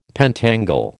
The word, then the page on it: pentangle: American English